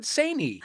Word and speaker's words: Saini: Also playing for the UK, also from the BBC Asian Network, and from sketch duo Egg, it's Emily Lloyd Saney.